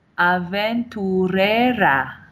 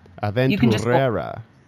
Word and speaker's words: Aventurera: Aventura, right? I missed a syllable. – Aventurera. – Aventurera. – Exactly. Yeah. – All right. You got to roll the R.